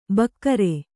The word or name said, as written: ಭಕಾರ